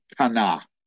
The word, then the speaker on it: qana: That's a Hebrew word, kana. We would spell it Q-A-N-A. If you transliterated that into English, Q-A-N-A, kana.